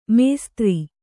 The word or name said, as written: ಮಾಸ್ತರ